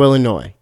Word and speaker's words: Illinois: I butchered that. Chicago, Illinois is the correct way to say that.